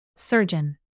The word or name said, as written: surgeon